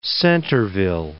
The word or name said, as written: Centerville